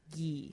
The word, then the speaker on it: ghee: By the way, it's pronounced ghee. – Ghee.